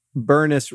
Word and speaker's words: Bernice: But then I remember years and years ago, I visited our cousin Bernice Rhymes with Furnace. – Yeah. – Hank in Tennessee. – It's important because it's not Bernice.